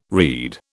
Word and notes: rede: British English

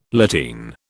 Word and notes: lateen: British English

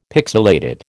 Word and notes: pixilated: American English

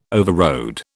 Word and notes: overrode: British English

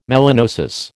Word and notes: melanosis: American English

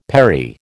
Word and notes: perry: American English

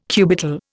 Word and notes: cubital: Indian

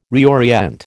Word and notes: reorient: American English

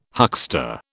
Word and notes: huckster: British English

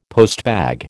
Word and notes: postbag: American English

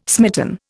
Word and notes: smitten: Indian

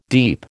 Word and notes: deep: American English

